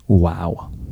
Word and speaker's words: Vav: My Hebrew professor told me to pronounce it, wow. – Oh. – Not Vav. So it's the ancient pronunciation.